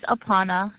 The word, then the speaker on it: Apana: Last name is Aparna, 96813. – Spell that last name for me. – A-P-A-N-A.